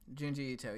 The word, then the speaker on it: Junji Ito: Junji Ito. Is that? Does that sound right to you? Yeah, Junji um, Ito. Junji Ito.